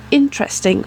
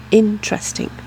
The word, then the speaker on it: interesting: Je me permets de faire une petite remarque, vous l'aurez peut-être entendu, mais entre le mot intéressant et le mot interesting, on perd une syllabe entre les deux. Intéressant en français a quatre syllabes, interesting en anglais en a trois, parce que la syllabe du milieu est vraiment mangée en tra. Intéressant devient interesting.